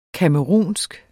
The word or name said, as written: camerounsk